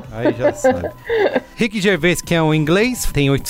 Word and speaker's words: Gervais: Assim que se pronuncia, Marco Melo? – Rick Gervais. – Grande fã.